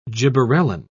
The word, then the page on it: gibberellin: British English